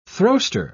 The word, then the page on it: throwster: British English